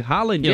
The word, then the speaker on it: Haaland: Matias tá engraçado aí por causa do Haaland, que a pronúncia correta de Haaland é Haaland, Matias Brasil.